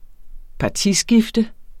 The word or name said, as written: partiskifter